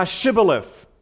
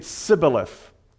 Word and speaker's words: Shibboleth: And if he said no, then they would say to him, "Then say now Shibboleth." But he said Sibboleth, for he could not pronounce it correctly.